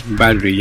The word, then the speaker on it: Variety: Porque en una reciente entrevista concebida a una revista que se llama Variety, algo así se pronuncia, no sé si lo pronuncié mal. – Variety, yes.